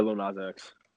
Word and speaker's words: Lil Nas X: Yeah, Lil Nas X.